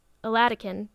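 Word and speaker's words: Oladokun: Her name is Joy Adelakin, or no, Aladikin, that's how you pronounce it.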